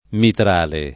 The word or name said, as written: mitrale